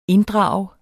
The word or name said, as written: inddrage